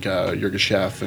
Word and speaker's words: Yirgacheffe: And all of my favorite coffees are like African, like Yirgacheffe and Sidamo. Is that how you say it? – Yeah.